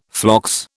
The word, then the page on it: phlox: British English